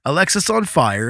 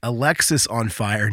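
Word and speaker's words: Alexisonfire: But Alexis on Fire, and yes, it is pronounced Alexis on Fire, not Alex is on Fire, is a Canadian post-hardcore band that formed in St. Catharines, Ontario in 2001.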